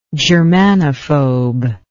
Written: germanophobe

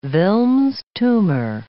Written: Wilms' tumor

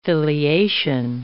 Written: filiation

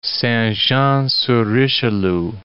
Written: Saint-Jean-sur-Richelieu

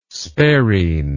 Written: Sparine

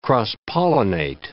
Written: cross-pollinate

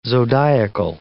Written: zodiacal

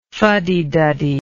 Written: fuddyduddy

